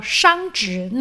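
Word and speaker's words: 熵值: 刚刚口味谈到有一个字叫做商职，那个字的写法是火字旁、然后在一个商人的商的一边，这个字也念作商职。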